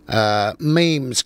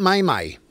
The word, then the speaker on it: meme: So if you're on social media, memes go up. So it's M-E-M-E, meme, okay? So it's spelled M-E-M-E, but it's pronounced M-E-E-M, M-E-E-M. Not May-May.